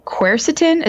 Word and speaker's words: quercetin: and quercetin. Is that how it's pronounced? Mm-hmm. Yeah. Yeah. Yep.